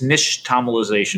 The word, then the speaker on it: nixtamalization: You know, it's an Aztec word, so it's very, very hard to pronounce. It's nixtamalization.